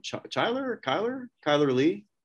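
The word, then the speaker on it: Chyler Leigh: Kyler Lee. – Okay. Yeah. It looks like Chrysler with the R and S missing.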